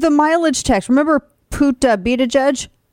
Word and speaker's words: Buttigieg: Buttigieg. Like be the judge. Right? No, be the judge. Pete Buttigieg.